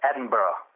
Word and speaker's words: Edinburgh: First of all, can you say it correctly to make sure that I don't mispronounce it, because I've been known to say it wrong in the past. I say Edinburgh. – Yeah, it's Edinburgh.